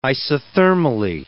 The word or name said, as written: isothermally